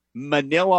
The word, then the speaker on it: Manila: Manila's gym, Manila, Arkansas, rhymes with vanilla.